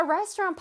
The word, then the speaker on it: restaurant: 자, restaurant 발음 한번 해볼까요? Restaurant 아닙니다. Restaurant 아니고요. Restaurant. 끝에 랑 소리가 아니라 언 소리를 내주세요.